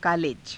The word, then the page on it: kaaleja: Hindi